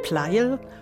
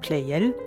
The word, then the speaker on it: Pleyel: Als Ignaz Josef Pleyel oder Pleyel, wie der Name heute meist ausgesprochen wird, 1747 in Niederösterreich als vermutlich 24.